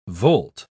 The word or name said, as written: volt